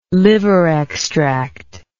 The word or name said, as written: liver extract